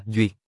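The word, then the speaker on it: duyệt (說): Chữ thuyết, đằng trước không đọc thế, phải đọc là duyệt.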